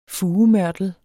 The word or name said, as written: fugemørtel